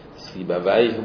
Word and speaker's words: سیبویه: دو تا تر الان تو شیراز میگن خیابون سیبویه، خیابونه است که قبر سیبه میگن اونجا هست، ولی تلفظ عربیش ویه بوده، سیبه ویه بوده، تلفظ فارسیش سیبویه بوده.